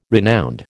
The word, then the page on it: renowned: American English